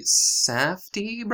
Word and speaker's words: Safdie: So, actually, speaking of the Safdie brothers? – Yes. – Is that how you say it? – Yep.